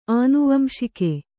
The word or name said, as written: ಆನುವಂಶಿಕ